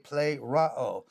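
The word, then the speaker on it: pleroo (πληρόω): We have the Greek word pleroo, P-L-E-R-O-O. The E is pronounced as an A, pleroo.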